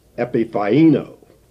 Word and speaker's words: epiphaino: But the word here is epiphaino. I'll spell that E-P-I-P-H-A-I-N-O.